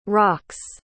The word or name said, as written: rox